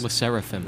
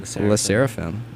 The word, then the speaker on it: Le Sserafim: How do I pronounce this? – Le Sserafim. – Le Sserafim.